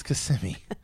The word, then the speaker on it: Kissimmee: No, it's not. It's Kissimmee. – It's spelled Kiss Me.